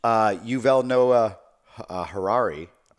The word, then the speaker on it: Yuval Noah Harari: Anyway, the author, Yuval Noah Harari, I may be saying his name wrong.